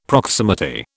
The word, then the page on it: proximity: British English